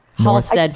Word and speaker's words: Halsted: You say North Halsted or North Halsted or... – Halsted. – Halsted. – We pronounce it. – Yeah, yep. the H is there, unless you're from the Sayo.